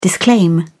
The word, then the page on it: disclaim: British English